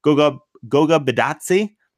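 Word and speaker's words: Goga Bitadze: Goga Bidatsi.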